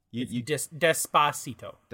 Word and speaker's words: Despacito: Also, the fact that you're pronouncing that middle syllable incorrectly, which, to be fair, it's not English. – So go ahead. – Despacito. – Despacito? – Yeah. Well, I mean, don't put the accent on it.